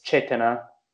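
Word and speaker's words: cetanā: Also, I think I remember there was the Pali term for intention, and it's chetana. – Okay. – It's volition, intention, or directionality. – How do you spell that? – It's C-E-T-A-N-A, and the last A is long.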